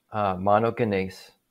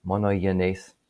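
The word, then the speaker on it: monogenes (μονογενής): So the term monogenes, and I'm not 100% sure I'm pronouncing it absolutely correctly, probably the most technical pronunciation would be to soften the G and make it monogenes, something like that.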